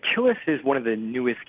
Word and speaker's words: Kilis: And there's a refugee camp, I believe it's at Kilis, K-I-L-I-S.